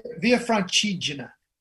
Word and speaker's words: Via Francigena: Is that how you say it? Via Francigena? – You came pretty close. So I'm going to give you a B on that. – Uh-huh. – Via Francigena. – Via Francigena.